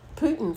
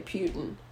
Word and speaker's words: Putin: Well, Putin, for one thing. – No, that's how you say it. – I know, but I used to say Putin.